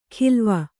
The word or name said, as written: ಖಿಲ್ವ